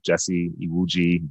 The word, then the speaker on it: Jesse Iwuji: Just type in my name, Jesse Iwuji, J-E-S-S-E, I-W-U-J-I.